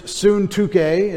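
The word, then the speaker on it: Syntyche: In Philippians 4:2, I urge Euodia, and I urge Suntuke, is how you would say her name, to live in harmony.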